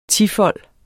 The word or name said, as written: tifold